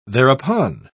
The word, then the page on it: thereupon: British English